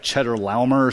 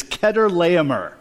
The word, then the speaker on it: Chedorlaomer: Now, it looks like Cheddar Laumer or something like that. But according to the internet, and you can always trust the internet, the pronunciation is Chedorlaomer.